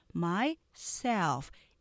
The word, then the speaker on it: myself: myself 하고 self를 이음절로 하시면 안 되고요. myself 이름절로 하셔야 돼요.